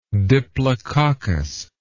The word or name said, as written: diplococcic